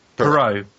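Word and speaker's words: Pierrot: Is it Piero? Piero? – Is it, how's it spelled? – P-I-E. Mm-hmm. Double R O T. – Mm-hmm. Perot.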